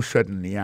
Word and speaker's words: certainly: Alors, en parlant de accent, regardez bien pour un mot certainly, accent tombé sur le premier syllabe là.